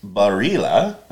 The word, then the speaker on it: Barilla: But this is Barilla. I had to look that up to make sure that I was pronouncing it in the authentic Italian way.